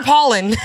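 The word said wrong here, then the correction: Pollard: No, Tiffany Poulard. She makes. – Damn, did I say her name wrong? Yo, don't cancel me. – Tiffany Poulard, honey.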